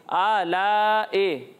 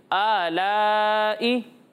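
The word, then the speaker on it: آلاءِ: آلائے یہ غلط تلفظ ہے ٹھیک ہے اے نہیں ہے یہ آلائی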